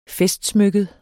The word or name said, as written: festsmykket